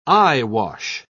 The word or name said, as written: eyewash